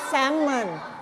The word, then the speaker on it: salmon: แต่ภาษาอังกฤษอ่านว่า salmon salmon ไม่ออกเสียง l นะ